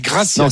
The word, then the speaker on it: Gracia: C'est Gracia. Tu as raison de bien le prononcer, c'est vrai.